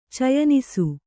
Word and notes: ಚಯನಿಸು: Kannada

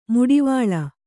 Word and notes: ಮುಡಿವಾಳ: muḍivāḷa

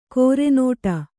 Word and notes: ಕೊರೆನೋಟ: kore nōṭa

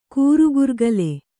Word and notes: ಕಿರಿಗರಕೆಲೆ: kirigarakele

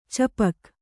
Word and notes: ಚಾಪಕ: cāpaka

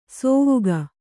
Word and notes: ಸೋವುಗ: sōvuga

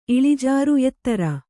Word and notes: ಇಳಿಜಾರು: iḷijāru

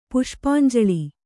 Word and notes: ಪುಷ್ಪಾಂಜುಳಿ: Kannada

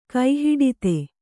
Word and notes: ಕೈಹಿಡಿತೆ: kai hiḍite

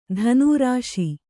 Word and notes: ಧನೂರಾಶಿ: dhanū rāśi

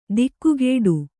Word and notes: ದಿಕ್ಕುಗೇಡು: dikkugēḍu

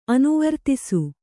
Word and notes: ಅನುವರ್ತಿಸು: anuvartisu

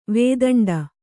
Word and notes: ವೇತಂಡ: vētaṇḍa; kannada

